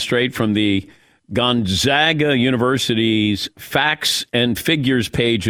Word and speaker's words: Gonzaga: Gonzaga is in bag. – Yeah, you've been saying it right all along. – Gonzaga. – Gonzaga. – But not Gonzaga. It's Gonzaga. – Gone. – Gone. – It's Gonzaga. – Gonzaga. – Gone. It's in Dawn. Gone.